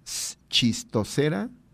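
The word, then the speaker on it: Schistocerca: Esquistocera gregaria, por si a ustedes les interesa consultar un poquito más en la red, para que la vean, vean esta especie, se escribe S y Che, Esquistocera gregaria.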